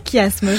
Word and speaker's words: chiasme: C'est que ça se prononce bien chiasme. J'ai eu la confirmation de la part de Zoé qui a écrit le questionnaire.